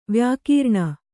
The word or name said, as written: ವ್ಯಾಕೀರ್ಣ